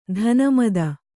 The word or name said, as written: ಧನಮದ